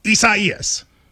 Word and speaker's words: Isaias: A lot of people, prayers to everybody involved, but it is Isaias. – Is that how you say it, though? – You do.